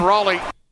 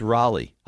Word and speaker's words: Raleigh: Can you guys say it correctly? – In Raleigh. – You guys are doing that to the Canes fans down here? That's what you're doing? – It's Raleigh.